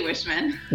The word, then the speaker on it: Wiechman: It's actually Wishman.